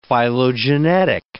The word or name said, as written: phylogenetic